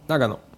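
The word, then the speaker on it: 長野: Значи в японския никога няма ударение, което да е много силно изразено и никога не е в последната сричка. – Ага. Нагано. – Значи Нагано.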